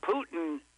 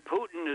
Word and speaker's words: Putin: And I was thinking Putin rhymes with Rasputin.